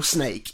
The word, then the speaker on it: Snak: And Snak is spelt S-N-A-K. And it's left the E off the end of the word snake, essentially.